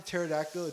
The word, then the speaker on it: pterodactyl: Spelling pterodactyl, it's a silent P.